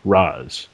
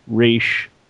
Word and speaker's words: Ra's: According to Google Translate, Scott is right, and the Batman animated series is wrong regarding how to pronounce Ra's. It's not Ra'sh, seemingly.